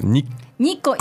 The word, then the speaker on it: Nikko: And so this is pronounced Nikko.